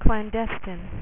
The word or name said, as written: clandestine